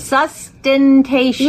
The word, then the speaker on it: sustentation: Sustentation. Sustentation. Four syllables. – Sustentation.